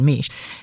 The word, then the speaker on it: Mech: There is a research biologist whose name is David Meech, and his last name is spelled M-E-C-H, so it looks like it would rhyme with tech, but it's pronounced Meech.